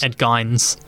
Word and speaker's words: Gein: Ed Gein's. Thank you for pronouncing that right.